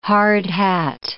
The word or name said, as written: hard hat